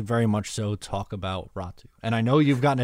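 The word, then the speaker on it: Räty: It is pronounced Ratu-ish differently. It's not E. It's not Ratty. It's Ratu.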